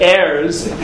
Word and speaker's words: heirs: Vidami, and I should compare it, Li Achim, to brothers, these are what's called the heirs. How do you pronounce that? H-E-I-R-S. Did I pronounce it right? Heirs. Okay.